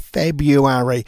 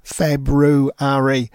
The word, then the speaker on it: February: Well, it is spelt with an R in the middle of it, and I hate it when people say February because it's February.